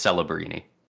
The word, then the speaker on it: Celebrini: How do you pronounce his name? Macklin Celebrini? Is that how you pronounce it? Or Celebrini? – I think it's Celebrini. – Celebrini.